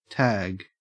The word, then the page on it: tag: Australian English